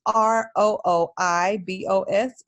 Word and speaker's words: rooibos: So it's probably the most healthy skin tea that you can have, and it's R O O I B O S, and it's pronounced like rooibos.